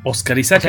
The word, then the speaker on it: Oscar Isaac: Oscar Isaac El Oscar también lo tienes que pronunciar distinto. Sí, pero el, artístico sí está tocado en inglés, es Oscar Isaac.